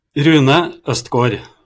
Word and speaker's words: Rune Østgård: Go on, say it correctly so that people can hear how to pronounce your name correctly. – Rune Østgård. – Østgård. Yeah. So the thing is, I love the idea of the Scandinavian languages and your and all those sort of weird tiny O's you've got on top and that change the pronunciation of the vowels.